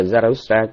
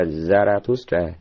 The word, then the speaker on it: Zarathustra: Tiếng Đức thì nó đọc là Zarathustra, còn tiếng Pháp nó là Zoroastre. Rồi còn bên tụi Đức nó đọc theo tiếng tiếng Ba Tư là Zarathustra, mục tiêu là Zarathustra.